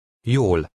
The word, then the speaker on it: Jól: Look at number 1, which has the Hungarian letter J. This is pronounced like the beginning of the English word yes. Try to read number 1. – Jól.